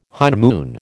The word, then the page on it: honeymoon: American English